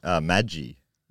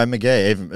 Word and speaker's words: Magie: but it's spelled M-A-G-I-E, which I would have pronounced Maggie. – Yeah, same. I think I would have thought it was that. – But anyway, McGee, it's way better.